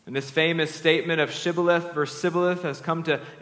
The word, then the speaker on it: Shibboleth: If he answered no, they told him, please say Shibboleth. If he said Sibboleth, because he could not pronounce it correctly, they seized him and executed him at the fords of the Jordan.